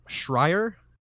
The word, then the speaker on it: Schreier: And in an update, Bloomberg reporter Jason Schreier, is how I'm assuming you say yeah. that. Yeah, That's how you say it.